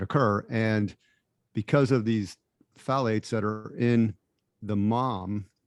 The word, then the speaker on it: phthalates: So I was listening to this podcast about a month or two ago, and they had a doctor on, and they're called phthalates. Is that the pronunciation? Phthalates. Mm-hmm.